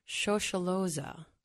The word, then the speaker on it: Shosholoza: I'm going to try to pronounce it Shosholoza. – Mm-hmm, that's right.